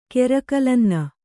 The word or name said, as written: ಕೆರಕಲನ್ನ